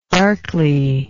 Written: darkly

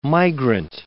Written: migrant

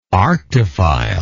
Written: arctophile

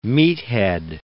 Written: meathead

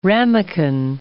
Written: ramekin

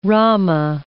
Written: Rama

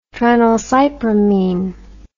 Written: tranylcypromine

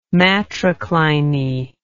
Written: matrocliny

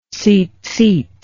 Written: zizith

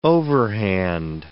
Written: overhand

